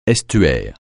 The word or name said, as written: estuaire